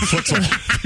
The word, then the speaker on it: futsal: Is it futsal? – Futsal.